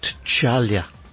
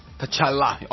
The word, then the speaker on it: T'Challa: T'Challa. – T'Challa. Almost got it. – T'Challa.